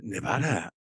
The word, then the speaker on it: Nevada: Something is snowy? – How do you say that? – Nevada. That's right. Nevada. Yeah, Nevada. Because of snow, precisely.